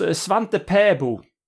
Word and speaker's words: Svante Pääbo: It was Svante Paabo.